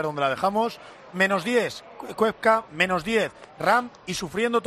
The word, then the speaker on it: Koepka: O sea, se pronuncia Koepka. – Los Yankees dicen Koepka, porque la O es junta una Koepka.